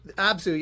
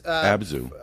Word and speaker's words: Abzu: Abzu. Yeah, it was Abzu.